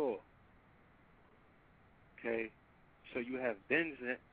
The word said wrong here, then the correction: benzene: I believe it's pronounced benzine, which is leukemia benzene. – And cancer causing. Yeah, benzene.